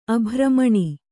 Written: ಅಭ್ರಮಣಿ

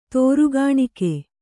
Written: ತೋರುಗಾಣಿಕೆ

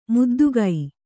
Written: ಮುದ್ದಿಗೆ

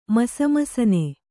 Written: ಮಸಮಸನೆ

